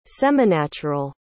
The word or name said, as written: seminatural